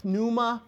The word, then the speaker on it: pneuma: Okay, so let's look at the New Testament and talk about how it uses the word pneuma or pneuma, however you want to pronounce that. The P is kind of silent, just like with psuche.